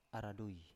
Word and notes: aradui: Biak

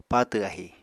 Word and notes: patw ahi: Apatani

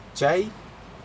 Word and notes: jai: Aneityum

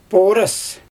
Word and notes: pooras: Xyzyl

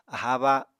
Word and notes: aháa ba: Apatani

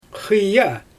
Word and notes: xɯja: Xyzyl

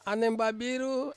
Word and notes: aneng babiru: Ansus

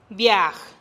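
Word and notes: biaj: Teotitlán del Valle Zapotec

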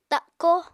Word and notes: takó: apatani

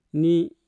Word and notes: ni: Mpur

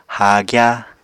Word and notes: haagya: Apatani

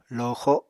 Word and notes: loxo: Apatani

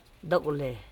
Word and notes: dokole: do.ko.le

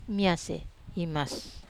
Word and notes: mas: Wamesa

